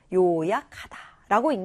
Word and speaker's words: 요약하다: 요약하다라고 읽는 겁니다. 왜냐하면 요약에서 기역과 하에서 히읗이 만나서 거센 소리를 내는 기역 발음으로 나는 거거든요. 그래서 요약하다 이렇게 나는 거예요.